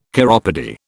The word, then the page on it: chiropody: British English